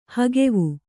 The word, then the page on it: ಹಗವು: hagavu